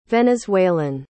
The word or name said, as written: venezuelan